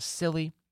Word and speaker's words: Scilly: Around 50,000 homes and businesses in Cornwall, Devon, and the Isles of Scilly. If you were wondering, by the way, that is spelled S-C-I-L-L-Y, but the C is silent, were without power.